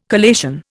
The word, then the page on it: collation: Indian